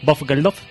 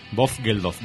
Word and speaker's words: Bob Geldof: El nombre, por favor, pronúncialo. – Bob Geldof. Vamos Bob a ver, Geldof. Aquí el 80% de rock and roll venís de Londres. Tenéis que tener esa pronunciación.